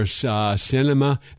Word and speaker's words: Sinema: Senator Manchin from Arizona and Senator Sinema, that's the right pronunciation, from Arizona.